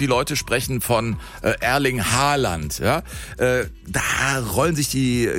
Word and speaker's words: Haaland: Er wird ausgesprochen Haaland, also mit O, ja. – Haaland. Und zwei A's werden als O ausgesprochen und ein A mit einem Kringel oben drauf ist eigentlich auch eine Aussprache, ein O.